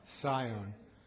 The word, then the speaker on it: scion: Rabbi Soloveitchik of Boston, the Rosh Yeshiva, the Rav of Yeshiva University, of course, was the, I always mispronounce this word, somebody help me, we need an English professor, S-C-I-O-N? Sion. Boy, am I glad I didn't mispronounce that. That would have been embarrassing.